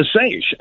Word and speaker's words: seiche: Is it called a seiche that they said? Is that how you say it? – Yeah, a seiche.